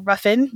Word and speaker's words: Ruffin: I'm going to give an honorable mention to Amber Ruffin, or Ruffin. Yes, I believe that's how you say it.